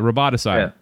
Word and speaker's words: roboticide: Detective Elijah Bailey is called to the spacer world Aurora to solve a bizarre case of roboticide. Is that how you say it? – Sure. – It's like homicide. – It's like, yeah, roboticide.